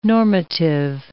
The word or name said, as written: normative